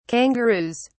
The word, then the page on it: kangaroos: British English